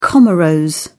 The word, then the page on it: comoros: British English